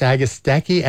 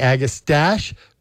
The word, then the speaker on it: Agastache: Agastache, Agastache, as it's spelled, but it's not a French word, my wife says, like mustache. It's Mustache. Mustache, Agastache, Agastache.